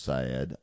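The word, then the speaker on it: El-Sayed: And please don't write me and say his name is pronounced Sayed. I know his name is pronounced Sayed, but it sounds goofier if you say Saeed.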